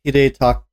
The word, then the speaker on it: Hidetaka: Critics' Choice Award, Red Dead Redemption 2, Lifetime Achievement, Hideo, Hide, uh, Hide, Hidekata Miyazaki uh, from Software. He's the Dark Souls guy. Hide, Hidetaka. Hidetaka. – Miyazaki.